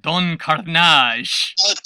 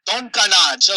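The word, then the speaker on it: Don Karnage: Don Carnage. – Don Carnage.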